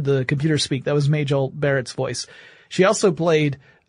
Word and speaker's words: Majel: And the project name at Google was Majel, or Majel, depending upon how you want to pronounce it, but Majel would be the way her name was actually pronounced.